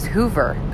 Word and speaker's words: Hoover: So my last name is Hoover, that with an H-O-O-V-E-R, and my license plate is Who, W-H-O-V-E-R, Hoover, right? So phonetically, yes, that is my last name, but it's, you know, it's my married name.